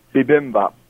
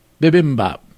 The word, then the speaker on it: bibimbap: When you mangled the pronunciation of the bimbop, that drove me to call in. – Yeah. Tell me once again what it should be. – Bibimbap. – Bibimbap. I like that much better.